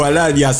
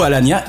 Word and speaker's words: Alagna: Eh oui, Roberto Alagna. C'est marrant, on a toujours envie de prononcer en italien alors qu'on sait qu'il est né à Clichy-sous-Bois, mais c'est plus fort que nous par rapport à ses origines.